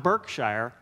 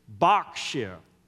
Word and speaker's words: Berkshire: It's not Berkshire, it's Berkshire. Is that right, Jeannie? Berkshire, yes.